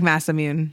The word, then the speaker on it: Masamune: I call it the Masamune. – Yeah, it looks like Masamune. – Yeah, that's how it's said.